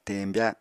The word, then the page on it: teñbya: Apatani